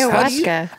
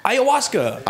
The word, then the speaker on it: ayahuasca: Ayahuasca. – Ayahuasca. – Ayahuasca.